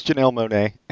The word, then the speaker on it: Janelle Monáe: It's Janelle Monáe,